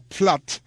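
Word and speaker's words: Pfaadt: Y en este mismo encuentro el abridor del equipo de Arizona, Platt, se llama, así se pronuncia, P-L-P-F-A-A-D-T, es el apellido.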